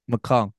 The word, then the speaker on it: McClung: You need to either get, like, with McCullough? Who won it? The dunk contest. How do you say his last name? – McClung.